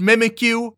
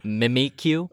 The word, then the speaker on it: Mimikyu: They introduced Mimikyu, Sorry, don't you mean Mimikyu? – Oh, yeah, Mimikyu, which is bullshit pronunciation.